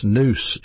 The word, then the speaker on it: nous: The word for mind in the Greek New Testament is pronounced nous, N-O-U-S.